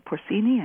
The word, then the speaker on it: porcini: But Belitis, that's one I think they call porcini. Is it porcini? I never can pronounce Italian. Porcini. – Porcini, I think. – Yeah, okay, thank you.